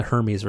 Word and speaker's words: Hermes: Because I just want to quickly see, because also that's the one thing I forgot, where sure, there's the Hermes, or sorry, I pronounced it properly, the Hermes version,